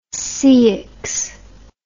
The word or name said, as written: ceyx